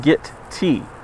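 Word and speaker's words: Gitea: I think it's pronounced Git T, since the IR tagline is good with a cup of tea.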